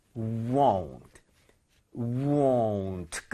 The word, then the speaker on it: won't: 자, 요거 발음할 때는 입 모양이 절대로 벌려지면 안 됩니다. 끝까지 잡고 있어야 돼요. 그래서 won't, won't 끝날 때까지 잡고 있어야 돼요. 가보세요.